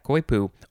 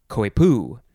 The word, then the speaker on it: coypu: Yeah, coypu. Or you can pronounce it coypu. Emphasize either syllable, C-O-Y-P-U. Noun from 1793. And the synonym is just nutria, N-U-T-R-I-A.